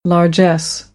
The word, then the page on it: largesse: American English; ɑːr